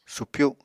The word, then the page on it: supyu: apatani